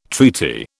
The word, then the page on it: treaty: British English